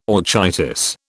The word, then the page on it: orchitis: British English